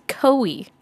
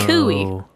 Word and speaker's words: Coie: So I saw that they formed in 2021 by the law firm Perkins Coie. – Oh, not Perkins Coie, no. – Coie. I didn't know how to say the name, so I looked it up.